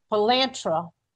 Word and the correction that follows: Palantir: Am I Palantir. – pronouncing Palantir? Thank you very much.